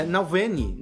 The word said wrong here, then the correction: Navalny: Nalvene. Navalny. Navalny, Navalny, falei errado.